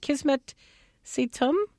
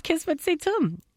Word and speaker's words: Kismet Sitam: The track we're about to hear, is it Kismet Sitam? Am I saying that right? – Yes, perfectly. Perfect. – Okay, it's like I know what I'm doing. here is Kismet Sitam, and it is courtesy of our guest this week on Jazzology, Jazzman.